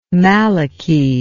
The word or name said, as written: maliki